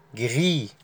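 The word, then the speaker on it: gris: رقم عشرة اللون اسمه اللي هو اللون الرمادي اسمه جري، جري، الاس لا تنطق، جري او جري.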